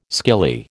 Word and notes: skilly: American English

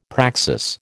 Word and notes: praxis: American English

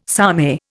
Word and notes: samey: Indian

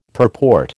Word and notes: purport: American English

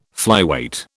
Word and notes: flyweight: British English

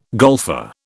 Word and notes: golfer: British English

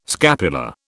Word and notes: scapula: British English

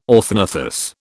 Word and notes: orthognathous: British English

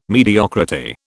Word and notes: mediocrity: British English

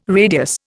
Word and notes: radius: Indian

